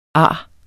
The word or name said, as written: arret